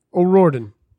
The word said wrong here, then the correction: O'Riordan: I think I'm saying it wrong still. – Yeah, I think it was O'Riordan. – O'Riordan, yeah, there you go. It's Dolores O'Riordan.